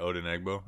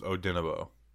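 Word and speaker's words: Odenigbo: So someone told me I've been saying it right. Odenegbo. It's Odenebo. – So what is it? Is the G silent? – Yeah, Odenebo.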